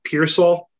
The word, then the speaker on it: Piersall: If you guys want to give me a follow on Twitter, my username is RT Pearsall. My last name is spelled P-I-E-R-S-A-L-L.